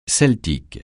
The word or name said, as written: celtique